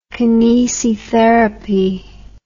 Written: kinesitherapy

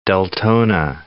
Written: Deltona

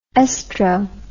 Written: estro

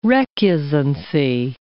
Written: recusancy

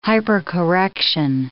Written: hypercorrection

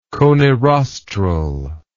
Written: coniroster

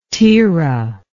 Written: Terah

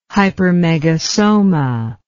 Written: hypermegasoma

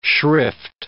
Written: shrift

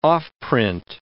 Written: offprint

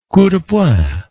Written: coup de poing

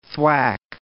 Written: thwack

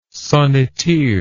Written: sonneteer

